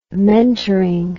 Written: mentoring

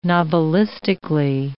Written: novelistically

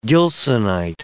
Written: Gilsonite

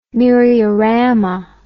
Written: myriorama

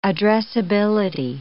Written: addressability

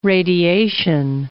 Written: radiation

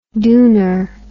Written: duner

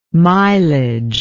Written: milage

